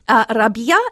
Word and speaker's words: Rabia: Rabia, as it's pronounced in Arabic, means spring.